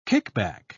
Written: kickback

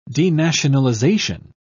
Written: denationalization

